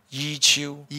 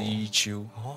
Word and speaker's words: 宇宙: 伊丘，一秋。 – 嗯，伊丘，就海陆是伊丘。